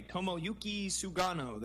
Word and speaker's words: Tomoyuki Sugano: Tomoyuki Sugano.